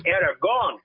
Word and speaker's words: Aragon: Aragon.